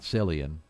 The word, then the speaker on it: Cillian: It spells C-I-L-L-I-A-N. It's not Cillian. He pronounces it Cillian.